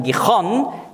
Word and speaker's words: Gihon: Gihon, hebräisch ausgesprochen Gichon, bedeutet die hervorbrechende, ich will sagen die hervorbrechende Quelle.